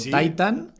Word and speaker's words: Titane: Eh, pues es la Titane o Titan. de la... o en francés Titan. – Titán. – Porque en francés la última letra no se pronuncia, entonces mm, digamos que titán femenino quedaría titán y titán masculino quedaría pues, eh, titán.